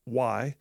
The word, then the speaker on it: Mulley: And it's Mulley. Sometimes it's spelled Mulli, but for the movie itself, it's spelled Mulley.